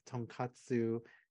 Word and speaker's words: tonkatsu: But yeah, so oh, the other part I want to mention before I conclude about my trip there was you want to watch out the pronunciation of tonkatsu and what is it? Tonkotsu, which is a pork-based ramen broth.